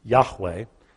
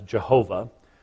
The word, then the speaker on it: Yahweh: You see it there in Hebrew, Yahweh. We slaughter it in English as Jehovah. That's actually a Germanic pronunciation that really isn't possible as having been the original pronunciation.